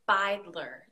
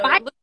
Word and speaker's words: Beidler: So it is German. It is Beidler. So Beidler.